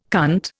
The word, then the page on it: cunt: Indian